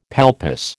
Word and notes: palpus: American English

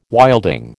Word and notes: wilding: American English

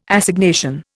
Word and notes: Assignation: Indian

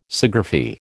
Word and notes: Sciagraphy: American English

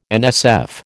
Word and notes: NSF: American English

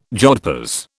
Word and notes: jodhpurs: British English

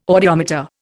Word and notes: audiometer: Indian

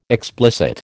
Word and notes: explicit: American English

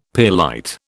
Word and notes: pearlite: British English

